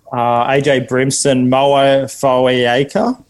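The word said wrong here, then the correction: Moa Fotuaika: Sorry for the pronunciations. – You've absolutely butchered that. Mo Fodawaker, mate.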